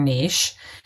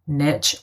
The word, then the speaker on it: niche: And those components together make up your niche. I mean, and you can say, by the way, niche or niche. It's a personal preference. I just think niche rhymes with itch.